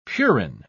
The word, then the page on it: purine: British English